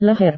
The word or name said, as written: leher